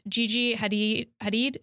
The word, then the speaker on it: Hadid: And, you know, of course, just to name a few of the women of the year, there was Gigi Hadid.